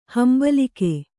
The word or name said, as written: ಹಿಂಬಾಲಕ